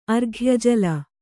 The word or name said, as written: ಅರ್ಘ್ಯಜಲ